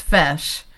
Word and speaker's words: feis: So the Columbus Fesh, which is spelled FEIS, is the Irish dance competition.